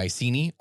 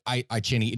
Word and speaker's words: Iceni: So Boudicca, for those of you who are unfamiliar, this was the Celtic queen of the Iceni, or Iceni. It depends upon where you are for how you're actually going to pronounce it. But the I hear it more often as the Iceni.